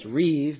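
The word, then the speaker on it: reeve (ריב): And the Hebrew for contend is derived from the word we've talked about many times, pronounced reeve, but looks like rib. It's reeve, R-E-E-V, and it refers to a lawsuit or a case that you are taking against someone in a court of law.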